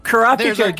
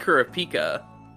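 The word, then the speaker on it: Kurapika: I've always said Karapika. – I think in Japanese it's Karapika.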